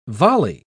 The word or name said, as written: volley